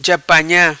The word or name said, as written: ja panha